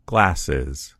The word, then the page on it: glasses: American English